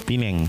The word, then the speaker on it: Penang: Uh, eh, LJ Radio masih di Malaysia dan Penang apa Pinang sih bacanya? Pinang. Pineng. Oh, Pineng. Masih di Pineng.